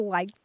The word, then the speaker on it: lack: L-A-C-K, lack. – L-A-C-K, but it's pronounced in the South and South Midlands often as like.